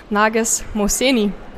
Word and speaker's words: Narges Mohseni: Ich habe am Spickzettel nachschauen müssen, wie man den Nachnamen ausspricht. Perfekt ausgesprochen, Nages Moseni. Sehr schön. Ich habe es noch nie nicht korrigieren müssen, das hast du gut gemacht.